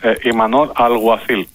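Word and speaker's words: Imanol Alguacil: Α, λοιπόν, Ιμανόλ Αλγουαθίλ.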